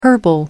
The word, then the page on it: herbal: American English